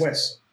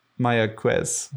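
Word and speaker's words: Mayagüez: Is that how it's pronounced? – Mayaguez. – Mayaguez. – Wes, yes.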